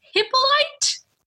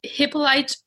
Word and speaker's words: Hippolyte: In the 1840s, Count Hippolyte, H-I-P-P-O-L-Y-T-E, Hippolyte. – Ooh, a count. – A count. So for the rest of the story, I'll refer to him either as Hippolyte or Count, but they're the same person.